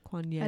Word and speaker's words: Kuan Yin: Yeah, Kuan Yin. Did I say that right? – Yeah. – Kuan Yin.